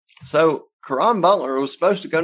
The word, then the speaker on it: Caron: This is Caron Butler.